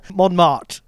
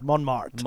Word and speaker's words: Montmartre: Montmartre. I've got to get used to these French pronunciations. – You really do. – How did I do? – Not bad. Montmartre. It is Montmartre.